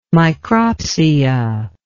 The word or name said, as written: micropsia